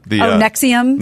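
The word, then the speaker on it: NXIVM: Nivixum. That was a good one. Is that how you pronounce that? – Nexium. – Nexium. – Yeah. It's spelled like that, though.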